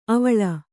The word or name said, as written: ಅವಳೆ